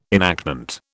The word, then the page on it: enactment: British English